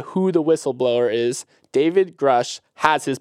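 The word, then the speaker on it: Grusch: Grush. – Grush.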